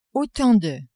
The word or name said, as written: autant de